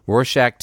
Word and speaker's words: Rorschach: Mom comes upstairs, takes the camera away, gives a Rorschach, how do you say it? – Rorschach test to the boy, which we don't even really get to see.